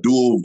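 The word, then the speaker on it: Deuel: And he was granted parole after 28 years and currently facilitates GRIP at, how do you pronounce that, Dual or Dual? – Dual Vocational Institute.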